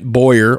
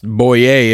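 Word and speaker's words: Bowyer: And Fox Sports analyst Clint Boyer, or pronounced Boyer if you're down in Louisiana, which is where he's from.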